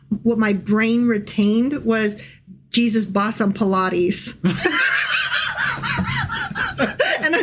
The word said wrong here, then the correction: Pilate: Is Jesus was brought before Pilate, as in Pontius Pilate. – Okay. – But it was spelled like Pilates. So it's going to be really hard for me to like, no, Pilate, not Pilates.